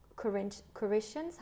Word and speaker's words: Corinthians: And that's from Corinthians.